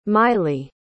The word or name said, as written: maili